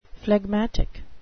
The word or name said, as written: phlegmatic